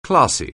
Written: classy

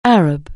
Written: Arab

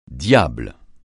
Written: diable

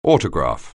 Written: autograph

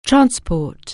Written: transport

